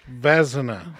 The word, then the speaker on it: Vezina: Vezina. – Yeah. – Vezina. – Vezina. – I think Vezina is the proper pronunciation.